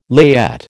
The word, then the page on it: layette: American English